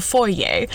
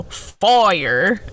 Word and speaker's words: foyer: Terry asked where Steve was, and she replied he was at the bottom of the stairs in the foyer. But Terry pronounced it in the video foyer. It was really funny, but foyer, you know, for us fancy people.